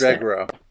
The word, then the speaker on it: Mandragora: And also as a callback to Mask of, I never know, is it Mandragora or Mandragona? I never know. How do you pronounce Mandragora.